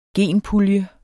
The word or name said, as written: genpuljer